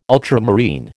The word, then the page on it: ultramarine: American English